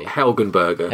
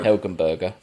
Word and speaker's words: Helgenberger: Well, the cast is Ben Kingsley, Michael Madsen, Alfred Molina, Forrest Whitaker, Marge Hexenberg, who is big from CSI, basically. – Helgenberger. – Helgenberger, thank you.